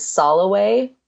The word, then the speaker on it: Soloway: And my email address is Soloway, which is a weird name. It sounds different than it's spelled.